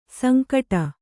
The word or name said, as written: ಸಂಕಟು